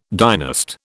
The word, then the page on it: dynast: British English